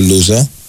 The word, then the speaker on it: Λούζα: Εδώ ένας φίλος γαλλόφωνος λέει ότι το σωστό είναι να τονίζεται στο Λου ο τόνος, ο Λούζα. Λέει ότι είναι Λούζα.